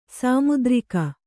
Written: ಸಾಮುದ್ರಿಕ

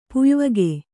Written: ಪುಯ್ವಗೆ

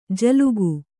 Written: ಝಿಲ್ಲಿಕೆ

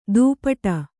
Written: ದುಪಟ

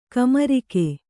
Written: ಕಾಮರಾಗ

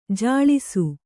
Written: ಜಲಸ

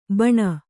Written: ಬಾಣ

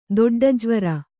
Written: ದೊಡ್ಡಜ್ವರ

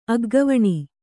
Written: ಅಗ್ಗವಣಿ

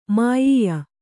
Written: ಮಾಯೀಯ

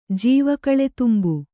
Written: ಜೀವಕಳೆತುಂಬು

